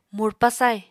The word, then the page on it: murpa sai: Apatani